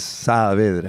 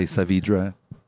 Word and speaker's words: Saavedra: Yeah, well, if you pronounce it in Spanish, it's Saavedra. – Saavedra. – Okay. And in English, whatever. I mean, people will say Saavedra.